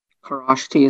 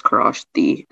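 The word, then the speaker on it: Kharoshthi: Karashti, is this Hindi? – It's from Sanskrit. – Oh, okay. May I have all the information? – Yeah, so the only pronunciation is Karashti.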